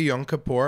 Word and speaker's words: Yom Kippur: So happy Yom Kippur. Am I pronouncing that correctly, Emily? – You are.